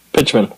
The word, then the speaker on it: Pichman: Pichman. – It's Pichman. Okay. – We went through that. – As long as you pronounce it with a P, not a B, we're good.